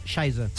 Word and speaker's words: Scheiße: Scheiße. T'ho juro. – És que ho he posat... – Scheiße.